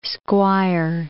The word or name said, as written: squire